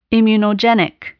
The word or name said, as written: immunogenic